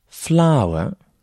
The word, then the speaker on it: flour: Quatrième mot, flour signifie la farine. Flour. La prononciation est exactement la même que pour une fleur en anglais, a flower.